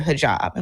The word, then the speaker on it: hijab: Even if you don't know exactly why, people do know, like, they don't want to be seen without their hijab.